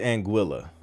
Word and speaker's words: Anguilla: Like, listen, hey Google, how do you pronounce Anguilla? It's Anguilla.